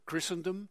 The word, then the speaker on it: Christendom: Een inleidend hoofdstuk over The God of Israel After Christendom. Christendom lees je, maar moet natuurlijk op zijn Engels uitspreken.